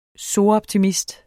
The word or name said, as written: soroptimist